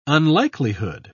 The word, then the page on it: unlikelihood: British English